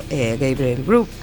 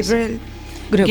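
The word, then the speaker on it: Gabriel Grub: Aquí un sepultureiro chamado Gabriel Grubb observa... – Que ben pronuncias, a verdade, sí. Es así. – Gabriel Grubb. – Gabriel, sí. – Grubb. – Ben.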